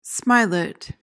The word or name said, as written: smilet